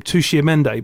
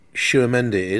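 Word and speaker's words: Tchouaméni: We have both been saying it wrong, and you picked up yesterday on, because I always called him Tushimende, but you were calling him Tushi something, but no, it's not, is it? – Tshuamendi is.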